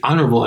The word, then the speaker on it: honor: So if you aspirate the H, you don't pronounce it. And you have one in English like that, which is honor. – Yeah. – So you don't say honor, and you don't say honorable. You say honorable and honor.